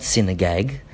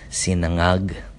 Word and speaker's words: sinangag: that's a word for you guys to say that are not Filipino, even you Filipino Aussies out there that can't say it properly, not sinagag, not sinangag, sinagag, it's sinangag, sinangag, even my daughter can say it, sinangag.